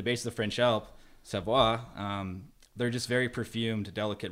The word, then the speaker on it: Savoie: You mean it's not Savor? – I'm like, "Is it Savoy, Savoie?" He's like, "A Savoie."